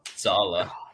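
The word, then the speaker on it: Saleh: And that's, of course, what Mr. Saley, is that how you say it? – Sala.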